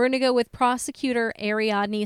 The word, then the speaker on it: Ariadne: Like Ariadne, like a web of Ariadne."